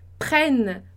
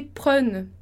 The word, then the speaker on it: prenne: Então, esse N a mais ali, ele tá para auxiliar, para fazer com que a gente pronuncie o E, prenne, prenne, no lugar de prenne, ok? Então, il faut que je prenne, com dois N's ali, o E anterior vai ser pronunciado com som de E.